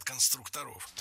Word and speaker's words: конструкторов: Вот именно конструкторская команда, навскидку, это сколько было человек, отряд конструкторов? – Конструкторов, конструктора, это неверно. В слове «конструктор», опять же, ударение не меняется. Всегда сохраняется на «у» во втором слоге. Конструкторы, конструкторов, конструктором.